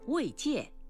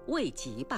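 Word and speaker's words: 慰藉: 草字头籍是多音字，一读籍，践踏、侮辱的意思；另一种读音戒，安慰、抚慰叫慰戒。您没有把它读成慰籍吧？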